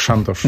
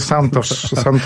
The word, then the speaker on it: Santos: Po portugalsku pani nie powiedziała tak, Santos. – Nie. – Bardziej takie Santos.